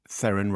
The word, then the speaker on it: Theron: She's obviously got used to Theron rhymes with heron. – Yeah. – But here we have higher standards and we go back to the Afrikaans.